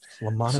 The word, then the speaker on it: Lamanna: Lamana's Bakery. Yeah. – Yeah. Yeah. It almost sounds French because it's spelled Lamanna. So Lamana, or, you know, like almost Nona, and then bakery.